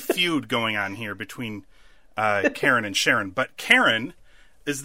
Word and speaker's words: Charon: The Charon from Greek mythology is actually pronounced Karen and not Sharon.